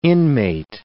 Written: inmate

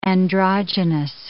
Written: androgynous

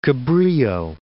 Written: Cabrillo National Monument

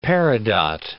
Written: peridot

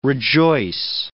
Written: rejoice

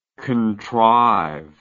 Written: contriver

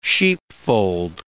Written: sheepfold

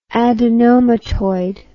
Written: adenomatoid

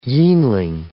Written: yeanling

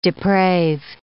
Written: deprave

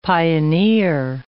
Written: pioneer

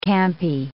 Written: campy

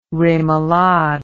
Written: remolade

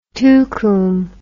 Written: tucum

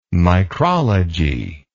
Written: micrology

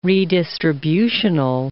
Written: redistributional

